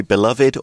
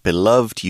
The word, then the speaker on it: beloved: Yeah, and you can say beloved or just beloved. You can sound that final syllable or not. Both ways of pronouncing it are okay.